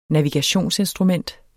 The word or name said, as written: navigationsinstrument